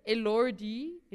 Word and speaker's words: Elordi: And Jacob Elordi, that's how you pronounce his surname.